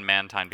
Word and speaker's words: Mantine: Yeah, and then we had, so the whole thing with the name and the fact that we settled on Mantine because Ramona pointed out to us that is anime canon in terms of how they pronounce it in the show. – Yes. – But I said that I thought it had to be Mantine because of the play on Marine.